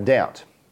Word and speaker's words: doubt: I think if you're ever going to doubt, just remind yourself that it rhymes with the word out.